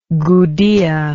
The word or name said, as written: gudea